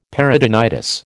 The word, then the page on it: peritonitis: American English